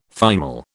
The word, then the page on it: thymol: British English